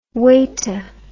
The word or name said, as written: weta